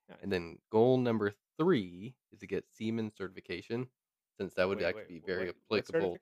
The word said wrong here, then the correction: Siemens: It's Siemens.